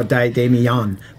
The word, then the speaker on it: Damien: It's just Damien Puckler, D-A-M-I-E-N Puckler, because everybody will hear emphasis on the E. – They always go Damien, but it's Damien.